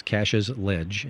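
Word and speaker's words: Cashes Ledge: For the folks who are wondering what we're talking about and want to go look it up, it's spelled like cash, like money, E-S, Cache's Ledge, and the Conservation Law Foundation and other groups are working to protect the place.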